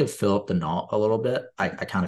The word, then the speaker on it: Danault: You don't pronounce the L and the T. – Danault.